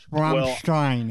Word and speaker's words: Rammstein: So I reacted to their video Du Hast, and I pronounced it Rammstein with a little effervescence, and I was told that I was correct in my pronunciation. So it's Rammstein.